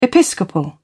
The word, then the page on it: episcopal: British English